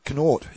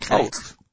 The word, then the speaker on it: Connaught: Connaught. Is that how you say it? – Yeah. – Not Connaught.